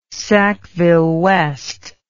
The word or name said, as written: Sackville-West